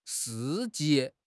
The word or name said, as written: 事迹